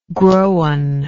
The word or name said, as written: growan